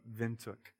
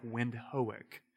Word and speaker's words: Windhoek: to plant Sovereign Grace Church of Windhoek, Namibia. And if you see it written, it looks like Windhoek. So don't be thrown off by that. You pronounce it Windhoek, Namibia.